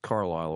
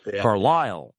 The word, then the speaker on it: Carlisle: And even Carlisle is not pronounced Carlisle or by natives. It's Carlisle.